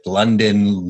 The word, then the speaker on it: Lundeen: You know, it's funny because growing up with the spelling of my name, L-U-N-D-E-E-N, no one could pronounce my name correctly. And it was like one of my things is like Lundin, Ludin. So I'm impressed you said it correctly.